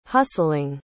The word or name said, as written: hustling